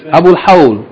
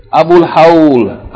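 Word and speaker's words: Abul Hawl (أبو الهول): Abul Hawl. It's called in Arabic, Abul Hawl. Abul Hawl.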